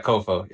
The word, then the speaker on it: COFO: And then is it pronounced COFO? – Yeah. COFO.